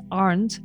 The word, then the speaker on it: Arndt: So Julia is clear, J-U-L-I-A. And then my last name is Arndt, A-R-N-D-T, and that's how you can find me on social media.